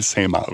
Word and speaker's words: Saint-Marc: il faut pas dire la basilique Saint-Marc, mais il faut dire la basilique Saint-Marc. Faut apparemment pas prononcer le C final,